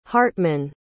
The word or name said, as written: hartmann